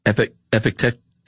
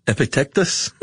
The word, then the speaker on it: Epictetus: It's that C going into a T that's getting you. – Yeah, I think it's just Epictetus or Epictetus.